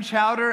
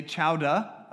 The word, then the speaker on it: chowder: You don't say chowder. You say chowda. Apparently, to pronounce the end of that word is very incorrect to someone who's from Boston.